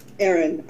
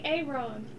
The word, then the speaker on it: Erin: Erin, okay. – E-R-I-N. – Ah, the other spelling. – Okay. – Not Aaron. – I was just going to say that. Aaron. – You're pronouncing it wrong.